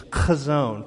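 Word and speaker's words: chazon (חזון): You know, if you dig into the Hebrew of that proverb, that word that we see is vision or revelation, the Hebrew pronunciation is chazon. Can you say that with me? Chazon. You got to get guttural with me. Chazon. Almost like you're coughing on someone, but don't cough on anybody, all right?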